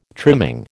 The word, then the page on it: trimming: American English